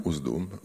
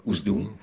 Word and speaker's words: Usedom: Usedom, alors je prononce peut-être pas très bien. – Usedom. – Usedom, voilà. Qui est donc un endroit en Allemagne.